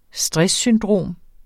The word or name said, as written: stresssyndrom